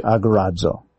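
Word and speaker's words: agorazo (ἀγοράζω): This is how you'd pronounce it in English, agorazo.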